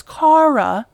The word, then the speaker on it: Kara: My name itself, which is Kara, but it's spelt K-A-R-A, is pronounced wrong all of the time by co-workers I've worked with for the last 6 years.